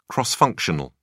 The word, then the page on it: cross-functional: British English